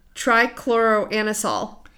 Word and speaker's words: Trichloroanisole: Trichloroanisole.